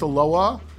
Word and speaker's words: Aloha: You don't pronounce the H in Aloha. It's just Aloha.